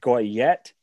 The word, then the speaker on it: Goyette: Moving on, Sudbury Wolves, David Goyette. – Ooh, nice pronunciation. You actually nailed that.